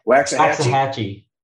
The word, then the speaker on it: Waxahachie: Waxahachie. – It's a strong X. I would have never guessed that. Why are these schools all of a sudden just matriculating to Waxahachie? What are they watching in Waxahachie?